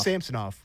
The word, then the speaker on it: Samsonov: I think we're done with fancy pronouncing it fancy. We're just going to do Samsonov. – Samsonov. It's Samsonov.